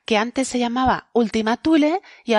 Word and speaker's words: Tule: Tule, en español lo podemos pronunciar eh, con nuestra T normal porque lo hemos tomado del latín. Aunque, como la palabra es de origen griego, si realmente queremos ser muy puristas tendríamos que decir Zule, con esa Z medio sibilante que, bueno, a quien le sale realmente bien, después de a los griegos, es a los canarios.